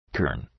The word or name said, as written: kern